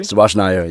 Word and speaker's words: Subhas Nair: Subhas Nair, yeah, you got it.